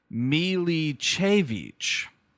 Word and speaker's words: Milicevic: Ivana Milsevich, I think is the idea here. Here we have the how to pronounce it on IMDb. – Here we go. – Milicevich.